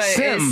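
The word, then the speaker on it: Sem: You did say Sam, but Sim. Sim. Because he's Dutch. Sim. He's Dutch. Sim. He's from the Netherlands.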